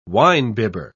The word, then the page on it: winebibber: British English